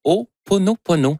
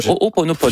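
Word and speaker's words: Ho'oponopono (Hoʻoponopono): Non, c'est une philosophie hawaïenne de, je vais le prononcer comme il faut, de Ho'oponopono. Le Ho'oponopono.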